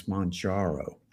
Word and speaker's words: Mounjaro: If you look at my podcast from today, then I always pronounce that wrong. It's Mounjaro. – I think we need to have a vote on that. I've been saying Mounjaro. I think Kilimanjaro. – Yeah. – Mount Kilimanjaro. So Mounjaro.